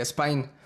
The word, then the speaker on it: Spine: Pero primero, cuéntanos, ¿está correcta la pronunciación de mi parte de su nombre? Spine. – Es correcta en su idioma original, que es alemán. – Pero nosotros, eh, platicando como banda, pues al final decidimos que específicamente para la banda la pronunciación sería como si fueran este, a palabra americana, que sería spine. Ok.